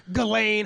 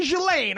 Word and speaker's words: Ghislaine: And all these people are like Ghislaine, Helene, and all these, and I'm like, no. Someone ask me. Her name's fucking Ghislaine.